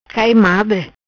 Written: Cai Madre

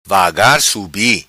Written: vagar subi

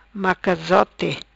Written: Macazote

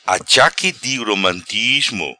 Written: Achaque di romatismo

